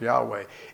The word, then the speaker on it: Yahweh: It used to be pronounced Jehovah. They've decided it should be pronounced Yahweh.